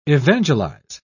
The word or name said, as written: evangelize